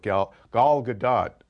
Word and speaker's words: Gal Gadot: And my interview with Gal Gadot, is actually how you say her name, she does talk about sort of that's how she becomes the Wonder Woman we know.